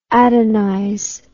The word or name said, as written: adonize